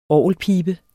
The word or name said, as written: orgelpibe